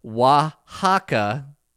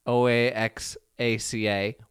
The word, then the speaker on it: Oaxaca: Sorry, guys, didn't mean to do that to you, but the word that we struggled with was Oaxaca, O A X A C A, Oaxaca Okay. I know, right? Kind of like how the X in Mexico is pronounced in Spanish, and it's Okay.